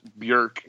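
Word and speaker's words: Björk: And first of all, it actually should be pronounced Bjork again because it's Bjork? The O with the two.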